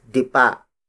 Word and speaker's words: dipa: The next ancient unit of length is dipa. Dipa. Dipa. Dipa is spelled as D-I-P-A.